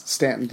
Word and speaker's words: Staunton: You pronounce that Staunton, hey? You just ignore the U? – Yes, Staunton, Virginia.